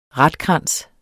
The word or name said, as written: ratkrans